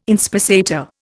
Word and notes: inspissator: Indian

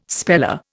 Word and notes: speller: Indian

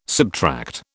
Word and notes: subtract: British English

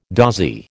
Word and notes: dozy: American English

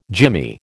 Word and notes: jimmy: American English

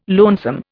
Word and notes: lonesome: Indian

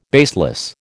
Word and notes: baseless: American English

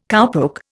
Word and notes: cowpoke: Indian

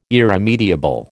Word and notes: irremediable: American English

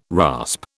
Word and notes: rasp: British English

